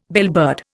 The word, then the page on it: bellbird: Indian